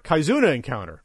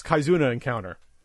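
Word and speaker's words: Kizuna Encounter: Kaizuna Encounter. And see, I'm not a Neo Geo expert. I said it wrong. It's Kaizuna Encounter.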